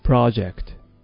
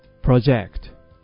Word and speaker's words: project: この project は名詞のいわゆる計画とかプロジェクト、こちらの場合は project、アクセントは前に置かれます。一方で計画する、予想するという動詞の場合は project、アクセントは後ろに置かれること、気をつけましょうね。